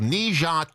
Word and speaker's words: Ne Zha: But it's actually a film called Ne Zha 2. Am I saying it right? – That's right.